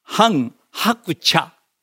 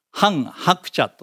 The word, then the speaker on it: 韓鶴子: で、奥様が、これね、本当は日本語では韓鶴子と読みそうだね。だけど韓国語でね、ハン・ハクチャ、ハン・ハクチャと読むんだそうです。